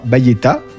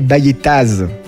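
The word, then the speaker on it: Baillettaz: Je parlerai de la Baillette sans prononcer le AZ final. Vous entendrez parfois Baïetta avec le A, mais jamais Baïettaz avec le Z. Prononcer ça, c'est un sacrilège. Le Z est presque toujours muet.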